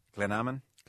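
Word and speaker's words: Glanamman: You mentioned there, and you can shoot me down here for the wrong pronunciation, your village, Glenamon. – That's very good, yeah.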